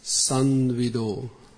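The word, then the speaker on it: samvido: So in this way you have to pronounce Sanvido.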